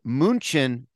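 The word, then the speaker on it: München: Game game in Munich, which I will remind you is pronounced Munchen in German.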